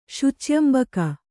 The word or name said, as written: ಶುಚ್ಯಂಬಕ